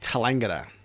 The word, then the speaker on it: Tallangatta: We went up to Tallangatta, the old flooded city, the flooded town of Tallangatta, which they how do you pronounce it? I call it Tallangatta.